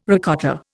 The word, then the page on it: ricotta: Indian